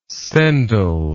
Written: sendal

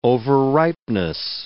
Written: over-ripeness

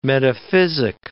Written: metaphysic

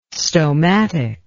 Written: stomatic

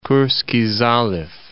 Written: Kurskiy Zaliv

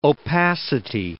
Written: opacity

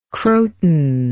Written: croton

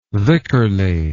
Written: vicarly

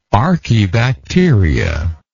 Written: archaebacteria

